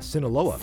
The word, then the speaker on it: Sinaloa: We got our Mexican drug lord, former leader of the Sinaloa cartel. Did I say that right? – Sinaloa.